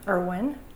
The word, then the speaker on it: Irwin: Joan Irwin, I-R-W-I-N.